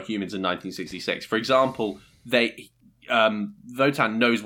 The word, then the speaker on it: Wotan: And Wotan. It's spelt the W. They do it the German way.